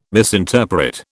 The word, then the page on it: misinterpret: British English